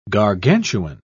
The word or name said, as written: gargantuan